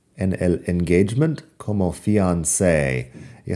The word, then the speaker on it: fiancé: En fin, eso es el fiancé que sería el prometido o la prometida.